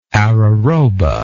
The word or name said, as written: araroba